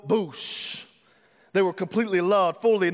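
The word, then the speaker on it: boosh (בּוֹשׁ): This Hebrew word ashamed is the word boosh is simply how you say it.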